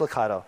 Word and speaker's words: Lucado: It's by an author called Max Lucado, right? Is that the right pronunciation? Okay, Max Lucado.